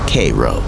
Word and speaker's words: Cairo: where the few local grog traders are careful to correct your pronunciation to Cairo.